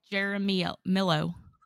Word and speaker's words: Jaramillo: Yeah, I spelled it wrong so I could say it right. And I still fucked it up. – It's a Jaramillo. – Yeah. Jaramillo. – There you go. Nailed it. – Yep. That's perfect.